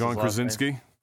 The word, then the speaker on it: John Krasinski: John Krasinski.